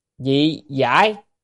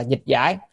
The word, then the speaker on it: dị giải: Tịnh Độ Thánh Hiền Lục Dị Giải. À, chữ này đọc là dị giải hoặc là dịch giải,